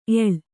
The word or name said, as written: ಎಳ್